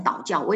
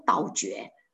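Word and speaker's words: 倒嚼: 在走兽类，走兽类它的洁净的条件呢就是两个，一个是要体分两半，一个是倒教，这个念成倒教，我以前都念倒觉，那这次改了好久把它改过来，因为我去听了那个两个都讲都念成倒教。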